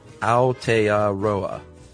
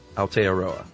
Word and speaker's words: Aotearoa: Aotearoa, or land of the long white cloud, is pronounced Aotearoa. Aotearoa.